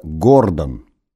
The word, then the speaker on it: Гордон: КЦ возглавляла Синтия Гордон. Ударение на первом слоге, чтобы, не дай бог, не перепутать, не попасть в созвучие с фамилией некоторых печально известных российских граждан.